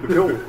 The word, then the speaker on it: fjord: Fjord. Spelled with a J. Like Jehovah spelled with an I. – Bjorn. – What's that? – It's a name. Bjorn. – Oh. – B-J-O-R-N.